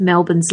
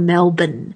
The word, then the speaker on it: Melbourne: And somewhere over the many, many years of Melbourne's life, it's become Melbourne. So that's how you say it, but we're very used to hearing people from overseas pronounce it Melbourne, which is fine.